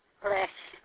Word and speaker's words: Lis: My name is Sylvia Liss. Last name is spelled L-I-S.